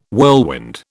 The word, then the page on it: whirlwind: British English